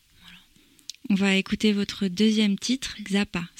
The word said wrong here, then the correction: Khara: Ça se prononce comme ça? – Eh bien, en fait, ça s'appelle Khara, ça se dit Khara.